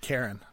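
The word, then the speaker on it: Charon: Yeah, I've always said Karen with the hard C, but whatever.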